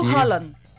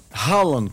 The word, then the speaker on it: Haaland: E o Haaland? – Ah, sim, gente. Ó, ia esquecendo. Haaland, como todo mundo chama, mas a Cláudia pesquisou e a pronúncia é Haaland.